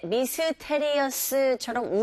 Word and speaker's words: mysterious: 이때 mysterious처럼 우리식의 말을 하지 마시고 mysterious, 이 miss 뒤에 t. 자, 이 t 사운드가 된소리 현상이 일어나면서 mister보다는 mysterious 이런 식으로 발음하시면 되고요.